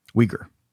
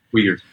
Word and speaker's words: Uyghur: Uyghur. – It is. – All right. – Uyghur.